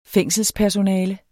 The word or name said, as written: fængselspersonale